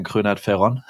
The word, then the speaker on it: Kröhnert-Ferron: So correct pronunciation would be Bastian Kröhnert-Ferron. And it's actually one part German, one part French, my last name.